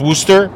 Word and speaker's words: Worcester: Kind of like it's Worcester, even though it's spelled Worcester. And in Massachusetts, FYI, roundabouts, not called roundabouts.